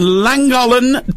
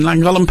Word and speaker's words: Llangollen: And Llangollen 2022.